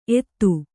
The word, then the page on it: ಏತ: Kannada